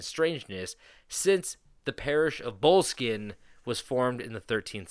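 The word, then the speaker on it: Boleskine: So, I'm going to tell you about the Bullskin House. So, and it's spelled B-O-L-E, Skin House.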